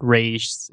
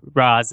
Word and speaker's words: Ra's: that it's not Raish, it's Raz, it's Raish. And the writer of that apparently researched how to pronounce Ra's al Ghul, and for whatever reason, he chose Raish the Hebrew rather than Ra's the Arabic, and intended giving the character Arabic Hebrew hybrid of name.